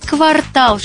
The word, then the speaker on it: квартал: Гулял по кварталу портной, устал, весь квартал шил наряд, а теперь у него выходной. В русском языке только ударение «квартал» соответствует норме, так что не забывайте об этом.